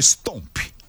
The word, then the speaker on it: stomp: A palavra é stomp. Stomp. Essa pronúncia, né, Tadeu? – É isso.